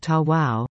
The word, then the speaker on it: Tawa: In the Cree language, Tawa, pronounced Ta-Wow, means, come in, you're welcome, there's room.